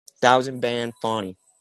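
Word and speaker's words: Thouzan Ban Fauni: It's like thousand, but with like a, like Zan, like spelled like the prescription, like Thousand, T-H-O-U-Z-A-N, and then Ban, B-A-N, Fonny, F-O-U, or F-A-U-N-I, Thousand Band Fonny.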